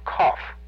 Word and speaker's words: Qoph: Now, there's another letter called Kof, which is spelled Q-O-P-H.